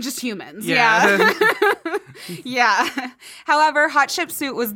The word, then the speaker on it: Hatshepsut: Hatshepsut, and my Egyptologist told us to say it like hot chicken soup.